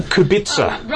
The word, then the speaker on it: Kubica: Kubica. – Robert. – Yeah. Robert. – Robert Kubica, his name is. I think it's pronounced K-U-B-I-C-A. Kubica, I think.